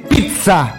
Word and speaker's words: pizza: Sin embargo, al momento de pronunciarse, debe ser adaptado al español y ahí hay que pronunciarlo, y esto es interesante, con una P-I-T-S-E-A, es decir, pizza. – ¿Cómo? – La pizza. Pizza. Con una T.